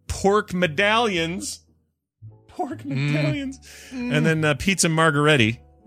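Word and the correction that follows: margherita: I think I'm saying that right. – No, margarita. Is it margarita? It's like pizza margarita, usually.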